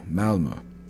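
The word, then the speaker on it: Malmö: It's spelled M-A-L-M-O, Malmo. Malmo,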